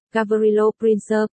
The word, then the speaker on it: Gavrilo Princip: Gavrilo Princip, Serbian Cyrillic, Gavrilo Princip,